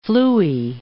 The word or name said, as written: flooey